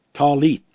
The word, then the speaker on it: tallit: Then, if you go on with this thing about this garment with the fringes, or if it's a prayer shawl, it's spelled T-A-L-L-I-T, and the I is pronounced like 2 E's. Talit, talit, talit.